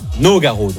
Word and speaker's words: Nogaro: Nogaro.